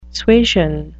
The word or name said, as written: suasion